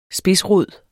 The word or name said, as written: SPIDSROD